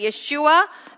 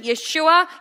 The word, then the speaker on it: Yeshua: That is the name Jesus in English, but Yeshua in his original pronunciation, Yeshua.